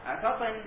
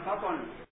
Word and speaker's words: فطن: قال المصدر المسبق من أن، فطن وفطن بكسر الطاء وضمها، بكسر الطاء وضمها وفتحها ثلاثة، فالأولى هنا أن تنطق مضمومة مراعاة للضمة التي هي قبل الكاف فيكن، التي هي قبل النون فيكن.